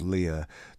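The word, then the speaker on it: Lyerr: Track 8 on Set To Go, episode 1, is from a four-piece band called Lyre. I'm going to spell this out for you now, just to save any confusion later on. L-Y-E-R-R. L-Y-E-R-R, and it's pronounced Lyre.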